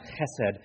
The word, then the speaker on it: chesed: The Hebrew word there is pronounced chesed, and here's what it means.